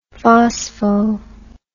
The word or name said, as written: phospho